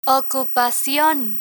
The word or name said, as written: ocupacion